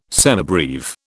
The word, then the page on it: semibreve: British English